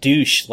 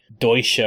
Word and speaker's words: Deutsche: So, yeah, it turns out that I mispronounced douche last time. And Ian, in the podcast feedback, so rightfully points out that it's probably not. It's something like Deutsche or something.